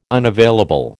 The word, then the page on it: unavailable: American English